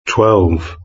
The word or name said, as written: twelve